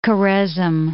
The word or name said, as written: Khorezm